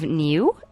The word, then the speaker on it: Niue: The South Pacific island of Niue is, I think, how it's pronounced, N-I-U-E, is issuing legal tender emblazoned with Star Wars characters.